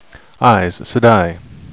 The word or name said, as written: aes sedai